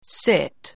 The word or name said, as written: sit